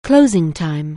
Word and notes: closing time: anglais

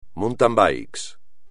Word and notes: mountain bike: espagnol

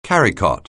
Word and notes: carrycot: anglais

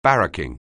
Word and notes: barracking: anglais

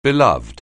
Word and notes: beloved: anglais

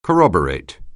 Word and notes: corroborate: anglais

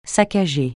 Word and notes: saccager: francais

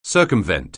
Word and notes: circumvent: anglais

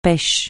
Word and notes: pêche: francais

